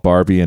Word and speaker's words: Barbie: Yeah, this one's called Barbie and Bam Bam. It's a song. I got the nickname Barbie at my last rehab. – Timely. – Yeah, yeah. I really like it. It rhymes with Harvey, you know.